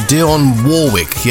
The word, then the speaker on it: Dionne Warwick: Nation Easy Radio with Dionne Warwick. Yes, that's the official pronunciation. That's how she says it.